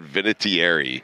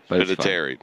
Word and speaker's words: Vinatieri: I am told that Vinatieri, am I saying that right? – No. – Vinatieri.